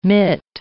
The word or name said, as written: mitt